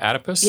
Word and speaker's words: adipose: Is the adipose, is that how you say it? Adipose? – Yes, adipose.